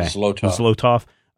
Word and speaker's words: Zlotoff: And so I think this is a opportunity for Zlotoff, or I think that's how you pronounce his name, to say Zlotoff. Okay. – Zlotoff.